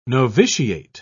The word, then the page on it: noviciate: British English